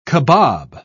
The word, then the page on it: kabob: British English